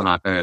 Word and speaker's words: Linate: Linate is how I say it.